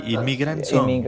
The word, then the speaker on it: Immigrant Song: Inmigrant Song. No sé si está bien pronunciado como lo... – Sí, Immigrant Song.